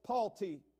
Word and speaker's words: Palti: There is, if I pronounce this right, it's Palti, P-A-L-T-I, Palti.